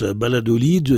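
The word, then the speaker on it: Valladolid: Ça se prononce Baladolid, et ce qui fait croire à certains que c'est Baladil Walid, le pays del Walid, qui était roi télé local.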